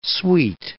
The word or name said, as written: suite